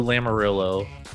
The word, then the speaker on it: Lamoriello: Islanders GM Lou Lamoriello, did I say that right? – Got it. Nice.